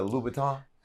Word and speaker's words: Louboutin: But last night, we had dinner with Christian Louboutin. Now, did I say his name right? Is it Louboutin? Because last night, you said it correct.